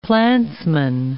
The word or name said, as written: plantsman